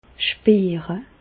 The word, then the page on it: spiire: Bas-Rhin; Reichshoffen